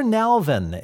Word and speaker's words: Nalven: Claire Nalven is on the phone.